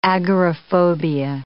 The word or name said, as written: agoraphobia